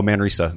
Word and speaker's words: Manresa: David Kinch, Messina. Am I saying that correctly? Messina? – Oh, Manresa. – Manresa. Yeah. Thank you.